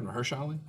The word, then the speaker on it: Mahershala Ali: This is the Mahershala Ali? – Yeah. – I'm saying that right? Mahershala Ali? – Yes.